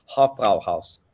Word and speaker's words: Hofbräuhaus: Wait, how do you pronounce it? Hofbräuhaus. That's right. – Perfect.